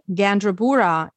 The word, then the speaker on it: Gandrabura: And also you can just use my full name, Anna, A-N-N-A, Gandrabura, G-A-N-D-R-A-B-U-R-A.